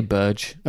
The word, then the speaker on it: Burge: How do you pronounce his surname? – I say Burge.